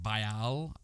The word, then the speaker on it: Bial: So the drug was created by a Portuguese company called Bial, I think is how it's pronounced, B-I-A-L, and